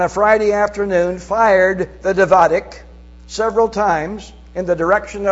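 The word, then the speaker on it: Davidka: The Israelis had a tremendously noisy mortar called a Davidic. Now I'm spelling a D-A-V-I-D-K-A. So I don't know if that's the pronunciation, but that's how I'm pronouncing it. David with a K-A on the end of it.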